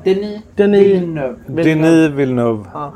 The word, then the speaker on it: Denis Villeneuve: Han har Denis Villeneuve heter han. – Är det så man uttalar? – Ja. Denis Villeneuve.